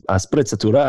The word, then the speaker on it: sprezzatura: I apologize to any Italian speakers out there, but it's sprezzatura, I think is pronounced.